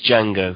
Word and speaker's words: Django: it's Django, because the D is silent.